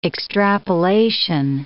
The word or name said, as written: extrapolation